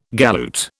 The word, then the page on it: galoot: British English